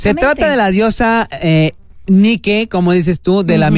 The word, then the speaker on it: Nike: Es una mujer que representa la Victoria, que se llama Nike, con acento en la E. Nike como Nike, como de los zapatos Nike.